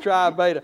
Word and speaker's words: Tri Beta: Tri Beta.